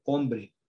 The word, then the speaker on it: hombre: Por ejemplo, hombre. Fíjense, la H no suena. – Ese es otro caso, es otro, otro, otro fenómeno. Hay una letra, hay algo, hay alguien que existe, pero no suena, que es la H, la H muda.